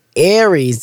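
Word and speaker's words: Ares: Aries, I'm sorry. Let me say that right. Aries, that's A-R-E-S, Get That House in 2023.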